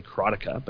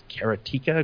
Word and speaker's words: Karateka: I always say Karateka, but it looks like it's Karateka according to some people.